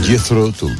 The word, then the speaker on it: Jethro Tull: Y me compré este disco de mira que lo pronuncio mal, Jethro Tull. Jethro Tull. – Jethro Tull, sí. – Jethro Tull. – Jethro Tull. – Grande.